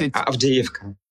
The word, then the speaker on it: Avdiivka: I don't know how you pronounce it, Avdiivka. It's a pretty important town, I guess. – Avdiivka.